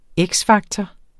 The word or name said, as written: X-faktor